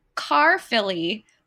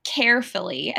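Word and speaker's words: Caerphilly: Carfilly, which I always want to pronounce this as like carefully. I think that'd be adorable.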